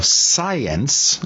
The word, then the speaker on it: PSIence: Jones, author of PSIence, and that's the way we're going to pronounce it because it's spelled P-S-I-E-N-C-E, subtitled How New Discoveries in Quantum Physics and New Science May Explain the Existence of Paranormal Phenomena.